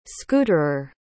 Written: scooterer

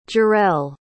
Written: jarell

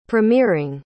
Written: premiering

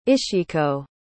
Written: ishiko

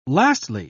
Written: lastly

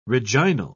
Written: reginal

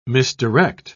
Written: misdirect

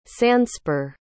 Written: sandspur

